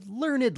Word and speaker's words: learned: Sometimes you will pronounce the E in adjectives, like learned. For example, the woman read a lot of books. She was a learned lady.